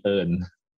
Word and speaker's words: Earl: Yeah, my my my Thai nickname would be Earl. Earl. Earl. Yeah. I like that. And and the Thai way of pronouncing it would be earn.